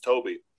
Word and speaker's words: Tobe: However, this generational tendency does not necessarily apply to those who bring up Tobe Hooper's, I think it might be pronounced Toby.